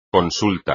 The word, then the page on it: consulta: espagnol